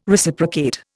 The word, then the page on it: Reciprocate: Indian